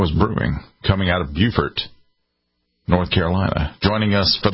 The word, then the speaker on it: Beaufort: In North Carolina, we pronounce it Beaufort, almost as if it was spelled F-E-R-T, and we leave Beaufort to South Carolina. It's a little confusing. Two states, same spelling.